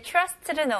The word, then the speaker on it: trust: 여기서 이제 TR로 시작하는데 느낌은 살짝 CH 발음으로 한번 해보세요. Trust, okay? Trust보다는 CH 발음. Trust, okay, very good.